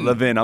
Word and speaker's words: Levin: He produces Mark Levin. I'm saying that right? – Levin.